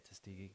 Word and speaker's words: Tuskegee: Like with the Tuskegee experiment, I can't pronounce it. – Yes. I think you said it right. – Tuskegee experiment.